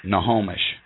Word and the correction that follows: Snohomish: It's not spelled Nahomish. It's spelled... – No, Snohomish. Snohomish. – Snuh.